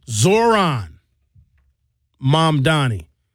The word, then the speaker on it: Zoran Mamdani: We're going to go through a few more of, I got to make sure I say his name right because I don't want to mess his name up. Zoran Mamdani. Talk